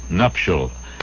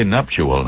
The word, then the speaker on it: nuptial: N-U-P-T-I-A-L. The nuptial ceremony, the wedding ceremony. Don't say nuptial now. Nuptial is correct.